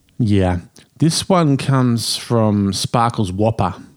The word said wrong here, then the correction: Whooper: Oh, sorry. No, no, I'm sorry. I've mispronounced his surname. Sparkles Whooper, expat from Jakarta, Indonesia, although he doesn't tell us where he is now.